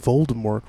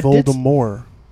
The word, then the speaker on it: Voldemort: It's actually Voldemort. – Really? No T. T is silent. – T is silent because it's Franche. That's correct. – Voldemort. – Yes, Voldemort. Okay. Mort or more meaning death in French.